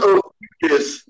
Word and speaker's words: Ophiuchus: But it's pronounced Ophiuchus.